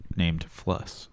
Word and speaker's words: Fluss: But it was credited to Steve Albini's cat named Fluss. – Fluss? – Spell it? – F-L-U-S-S. – Love it. – Like floss with a U.